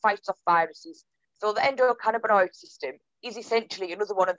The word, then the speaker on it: endocannabinoid: Obviously, you've mentioned the personal benefits for you, but I think this will help reduce the stigma, because you mentioned, I'll struggle to pronounce it, that it in a cannabinoid system. – Endocannabinoid system. – That's it.